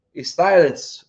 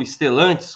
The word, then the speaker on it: Stellantis: Marcão, ele colocou o seguinte: a Stellantis ou Estelantes, como você queira pronunciar, né?